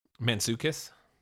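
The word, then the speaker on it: Mantzoukas: Mansukis.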